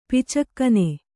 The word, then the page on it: ಪಿಚ್ಚುಗಣ್ಣು: piccugaṇṇu